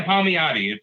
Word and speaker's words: Palmiotti: Yeah, Palmiotti.